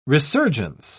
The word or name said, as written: resurgence